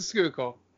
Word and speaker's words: Schuylkill: It is the Schuylkill. – It's good the Schuylkill. About to fall in. – You're so proud to say that. – I'm so proud.